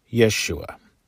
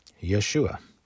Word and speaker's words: Yeshua: Joshua, whose name in Hebrew is Yehoshua, which in Aramaic, which was the language of the first century, is Yeshua. That would be the Aramaic pronunciation of Joshua. Yeshua.